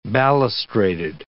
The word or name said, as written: balustraded